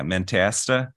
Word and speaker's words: Mentasta: And we pray for all the people at, I'm not sure if I'm pronouncing it right, Mentasta, is that right? Yeah, Mentasta.